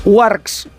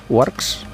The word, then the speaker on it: UARX: Mira, fijaos, Warx, ¿se dice así? Warx, U-A-R-X. – Es española, ¿eh? – Bueno, pero yo es que... – U-A-R-X. – U-A-R-X, vale.